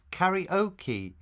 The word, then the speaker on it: karaoke: We pronounce it karaoke in English, with the emphasis on the O.